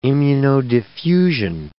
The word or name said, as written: immunodiffusion